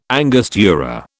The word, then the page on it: angostura: British English